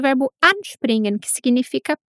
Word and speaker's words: anspringen: Outro detalhe: com verbos separáveis, a tônica, a parte mais forte da sílaba, recai no prefixo anspringen.